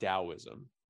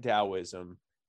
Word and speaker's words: Taoism: Another thing that you can look into is something called Taoism, and it's spelled T-A-O, not D-A-O, T-A-O-I-S-M.